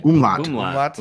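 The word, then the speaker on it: umlaut: Like an umulet over it. Is that how you say it? Umulet? Umulot? Umlai? – Umlot. – Umlot.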